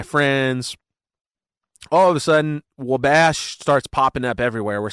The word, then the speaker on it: Wabash: and I see we're crossing Wabash River on the map. – Whoa. – Yeah, which is actually pronounced Wabash, but you know, yeah.